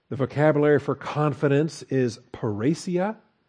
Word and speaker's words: parrhesia: Parrhesia, if you want to put the accent on the I there where it belongs, parrhesia. P-A-R-R-E-S-I-A, parrhesia.